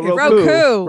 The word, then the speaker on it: Roku: Rocco will have a canary. – No, Roku.